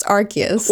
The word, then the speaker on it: Arceus: I thought it was Arceus, but I listened to the presentation yesterday and I specifically wrote it on the doc because I was like, I'm gonna forget, but it's pronounced Arceus.